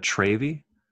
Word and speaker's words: Travy: So now, but now it's just T-R-A-V-Y-T, and I hate it. I'm so mad. – Do you feel like a Travy? – Yeah, that's how it. Yes, that's what I feel like. It's pronounced Travy, and I hate it.